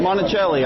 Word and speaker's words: Monacelli: Monacelli.